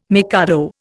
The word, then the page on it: mikado: Indian